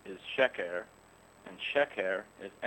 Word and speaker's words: sheker: and in the other word, it's also a double S sound, but it's sheker, with the accent on the first syllable.